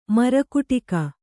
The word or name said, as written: ಮರಕುಟಕ